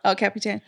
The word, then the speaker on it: El Capitan: She was climbing El Capitan. That's how you say it, right? – Yeah. – El Capitan.